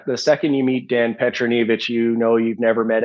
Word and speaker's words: Petronijevic: It's Petronijevic. – Petronijevic.